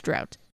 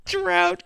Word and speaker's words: Draught: Drought Guinness. – Why wouldn't they just spell it draft? – Miller Genuine Drought.